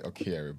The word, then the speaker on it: Okyere: and then Okyere is like Okyere, but then like, you know, I've vowed to always pronounce my name properly regardless of where I am.